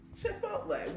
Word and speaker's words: Chipotle: Chipotle.